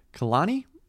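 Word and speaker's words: Kehlani: And then after that, we have the latest album from Kalani. I think that's how you say her name, right? – Yeah, sounds right.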